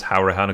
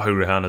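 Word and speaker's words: Conor Hourihane: And Connor Hourihan is apparently pronounced Hourihan, according to the man himself, not Hourihan, as I've called him for his entire career, basically.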